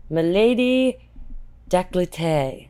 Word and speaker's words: Milady Décolleté: And Gillette launched their, I'm gonna mispronounce this, Milady Décolleté. Actually, I think that was good.